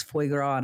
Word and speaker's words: foie gras: So one of the things that we got was foie gras. Is that how you say it? Foie gras.